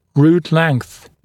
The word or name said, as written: root length